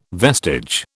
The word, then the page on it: vestige: British English